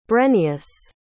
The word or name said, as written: brenius